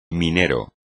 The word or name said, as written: minero